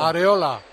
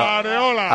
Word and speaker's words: Areola: Que cómo se pronuncia, Miguelito. No, perdón, Molchor. – Arreola. – ¿Cómo? – ¿Otra vez? – Arreola. – ¿Arreola o Arreola? – Arreola. Arreola. – Pepe Domingo Castaño. ¿Le damos el visto bueno a la pronunciación? – Arreola, hombre. Arreola, que es más fácil. – Pepe, ¿cómo lo digo? – Areola. – Claro. – Areola. Melchor, lo siento mucho. Pero, Areola.